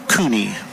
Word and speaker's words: Kohne: Ball on a strike to leadoff hitter Logan Cooney, K-O-H-N-E, pronounced Cooney.